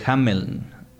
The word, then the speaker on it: Hameln: Hameln, originalmente así se pronuncia, bueno en alemán, Hamel.